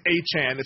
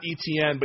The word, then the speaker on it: Etienne: And it's like, oh, actually, by the way, it's Achan. It's not Etienne, but you guys have been saying it wrong.